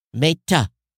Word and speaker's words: Meta: Pronounce the E as in egg. Meta. Meta. I thought I'm saying Meta, right? Not an A in maid.